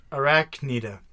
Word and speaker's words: Arachnida: Class, Arcanida. Arcanida. Our Canada. – Okay. – Arcanida. – So we have Europe and Canada. – Archanida really is Arachnida. Duh. It's Arachnida. – Wow. You butchered that one. – And then order is Aranei. – That's the one that has an A-E-A.